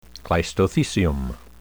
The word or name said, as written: cleistothecium